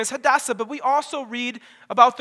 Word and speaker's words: Hadassah: And that's when Mordecai and Hadassah come on the scene.